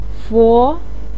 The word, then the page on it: fo2: pinyin